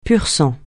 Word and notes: pur-sang: francais

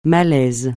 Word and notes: malais: francais

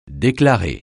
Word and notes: déclarer: French

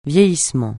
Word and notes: vieillissement: francais

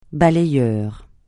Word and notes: balayeur: francais

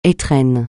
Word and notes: étrennes: français

